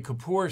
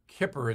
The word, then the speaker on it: Kippur: I say Kippur sometimes, but it's Kippur is a correct pronunciation.